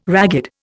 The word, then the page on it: ragged: Indian